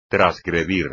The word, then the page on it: trasgredir: espagnol